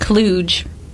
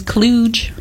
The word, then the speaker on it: kludge: Kludge. – You search a hard G like kludge? – It says kludge, like a J, kludge. – Oh, with a J? – Yeah.